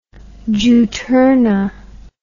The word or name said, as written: juturna